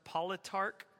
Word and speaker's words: politarch: I believe it's pronounced politarch, P-O-L-I-T-A-R-C-H.